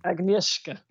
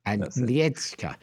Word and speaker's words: Agnieszka: How do you say her name? – Agnieszka. – Agnieszka. Your lovely wife.